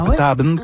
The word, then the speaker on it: Petaben: C'est comme ça qu'il faut prononcer Petaben. Hein? On voit écrit Petaben, mais c'est Petaben qu'il faut dire.